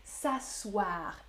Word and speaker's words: s'asseoir: Pas ça ce soir, non, s'asseoir. Et on ne prononce pas le E.